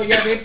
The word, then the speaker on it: Yahvé: Dice, yo le digo Yahvé porque yo estudié hebreo y así se pronuncia.